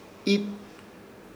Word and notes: Iit: Ishir Chamacoco

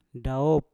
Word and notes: daop: Mpur

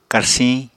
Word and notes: karsiñ: Apatani

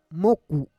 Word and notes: moku: Roon

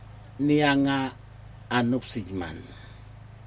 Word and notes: niaga a nupsijman: Aneityum

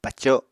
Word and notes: paco: Apatani

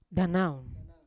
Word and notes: danan: Yawa

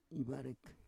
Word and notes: ivarek: Roon

